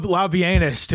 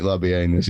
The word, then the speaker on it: Labienus: Yeah, fuck this pronouncing it Labienus. It's Labienus.